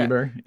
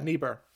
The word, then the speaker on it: Niebuhr: but it's Reinhold Niebuhr, I think. Is that how you say it? Okay. – Niebuhr. – Yeah, Niebuhr.